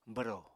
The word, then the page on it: bwro: apatani